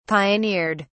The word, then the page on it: pioneered: British English